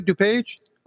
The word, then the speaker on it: DuPage: Am I saying that right, DuPage? – That's correct, yes.